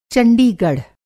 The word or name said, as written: चंडीगढ़